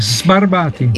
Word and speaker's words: Sbarbati: Con la S, Sbarbati.